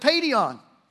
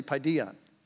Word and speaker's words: paidion: So to my M-R-E eyes, the words P-A-I-D-I-O-N looks like Paideon, but it's really Paideon, Paideon. And so I want to get that straight on the record. Didn't want to be speaking text in Greek to you in Paideon.